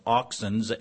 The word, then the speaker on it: auxin: We actually get our English word for plant hormones, which are pronounced auxins, A-U-X-I-N, from this Greek word.